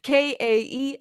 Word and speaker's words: Kaeley: all the good stuff under Kaeley Jade. My name is spelled kind of funny, but if you can figure it out, it is Kaeley, and then Jade, like the cool green rock.